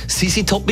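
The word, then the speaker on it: ZZ Top: Sisi Top mit Z. Die Anglistikstudentin Xenia Eigel hat uns aufgeklärt. Sisi Top ist ja eine amerikanische Band und darum tut man das Z auch als Sie aussprechen.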